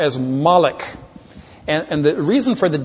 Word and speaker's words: Molech: And so they would take the proper name Milcah, and they would take the Hebrew word Boshet with the O, long O, and the short E, and they'd substitute the two vowels into the name Milcah, thus Molech.